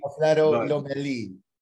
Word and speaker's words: Alfredo Lomelí: Alfredo Lomelí, right? – Am I pronouncing it? Lomelí. Yeah, man. Look, feel free to call me Freddy. It's Alfredo Lomelí. – Alfredo Lomelí.